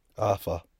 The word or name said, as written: aafah